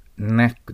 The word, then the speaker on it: knack: その次はクナックと読まないように。 K と N が一緒になると K の発音がね、消えます。 Knack です。